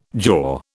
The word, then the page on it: jaw: British English